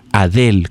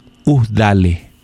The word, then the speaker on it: Adele: Mi nombre no se pronuncia Adele, como dicen ustedes. Se pronuncia Udale.